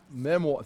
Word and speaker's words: memoir: Some of it appears to be a group of material, which we would call Nehemiah's memories, or the memo, how do you say that word, memoir, thank you.